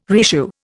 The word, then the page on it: reissue: Indian